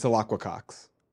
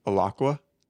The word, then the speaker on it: Alaqua Cox: It's Alacqua Cox. – Yeah, I wouldn't have been able to say that. – Can you say it back to me? – Alacqua? – You got it.